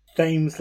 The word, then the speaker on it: Thames: Thames like James.